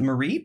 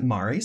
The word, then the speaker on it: Mari: And her father's sister, whose name is spelled, we would pronounce it Marie, but they pronounce it Mari, so that's how I'm going to pronounce it.